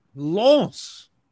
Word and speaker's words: Lens: we move on to Lons? Lons. Yeah. yeah. Your your pronunciation is getting better as we go on.